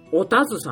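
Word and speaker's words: 田鶴: では、初恋の人に平井佳穂ではなく、おたず様っていうのが、田んぼの田に鶴と書いて、おたずですね。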